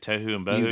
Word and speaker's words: tohu and bohu: Tohu and Bohu? – You got it right.